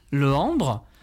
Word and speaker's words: Lehembre: Je m'appelle Cyril Lehembre, L-E-H-E-M-B-R-E, ça s'écrit comme ça.